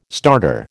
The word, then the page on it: starter: American English